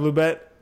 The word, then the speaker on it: Lubbock: We've got our co-host, Stephen Ferrer from Canvas Inc., and our very special guest out of Lubbock, Texas.